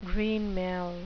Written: greenmail

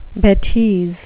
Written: betise

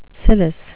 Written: cilice

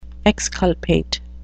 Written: exculpate

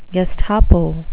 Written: gestapo

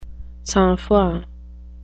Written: sang-froid

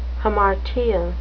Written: hamartia

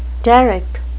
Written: derrick

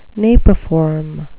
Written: napiform

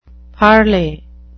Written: parlay

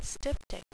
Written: styptic

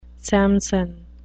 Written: samson

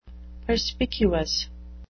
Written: perspicuous

